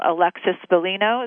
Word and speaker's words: Bellino: and I saw the beautiful Alexis Bellino. Is that how you say your name? Alexis Correct. – Bellino. – Correct. Yeah.